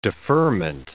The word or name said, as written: deferment